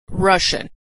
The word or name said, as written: Russian